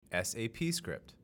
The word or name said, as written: SAPscript